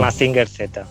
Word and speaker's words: Mazinger Z: Mazinger Z.